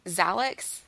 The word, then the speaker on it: Xhalax: And is it Xhalax? Is that how you yeah. say her name? Yeah.